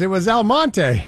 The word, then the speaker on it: Almonte: It was El Monte. – Really? – Huh. Yeah. So it's spelled A-L-M-O-N-T-E. Tricky little town. Elmont is actually in Ontario between the Ottawa and Arnprior, Carlton Place.